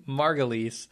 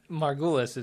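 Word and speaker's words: Margulies: How do you pronounce her name? Fuck you. – Margulis. Yeah, Margulis is hilarious, though.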